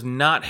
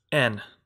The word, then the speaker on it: Ehn: Is Christopher N. I believe that's how you pronounce his last name. E-H-N.